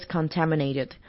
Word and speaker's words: contaminated: 然后 water is contaminated 这个字是污染的意思，它的重音在 ta，就第二个音节 contaminated。